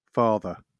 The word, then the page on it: father: British English